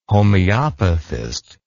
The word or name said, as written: homeopathist